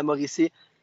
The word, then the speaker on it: Mauricie: Episode 19 is literally the pre classique de Canut de la Maurice. Did I pronounce that right? Uh, it's classic. – In French, it's classic International de Canots de la Mauricie.